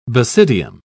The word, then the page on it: basidium: British English